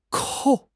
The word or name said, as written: 扣